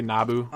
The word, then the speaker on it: Nabu: It's pronounced it's spelled N-A-B-U. Oh, okay. So I want to say Nabu. – Okay. Maybe Nabu. – Nabu, that's what it was.